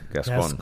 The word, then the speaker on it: Gascon: It almost seems like, I mean, going back to the DA, how do you pronounce it? Gascon? Is that? – Gascon.